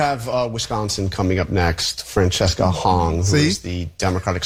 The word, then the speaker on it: Francesca: Francesca. Did I say that right? Francesca. – No, it's Francesca.